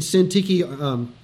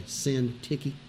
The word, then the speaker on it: Syntyche: It's used in verse 3 of chapter 4 where Euodia and Syntyche, that's how you say her name, by the way, Syntyche.